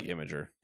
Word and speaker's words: Imgur: How do you say this? – I like Imgur. – Imgur, okay. That's how I feel as well.